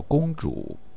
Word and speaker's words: 公主: Rick 等一群王老五最近常跟 Lydia 在一块玩，他们叫她“ 拱猪”。陈达纠正 D. Rick 的发音，那叫“ 公主”。